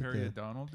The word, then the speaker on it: periodontal: Periodontal disease? – There you go.